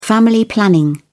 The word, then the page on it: family planning: British English